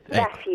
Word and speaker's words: Vafi: Allora, a proposito del ruolo del regime, citi un romanzo di una delle scrittrici più famose dell'Iran di oggi, correggimi se ne sbaglio la pronuncia, Fariba Vafi, come si dice? – Sì, Vafi, ecco.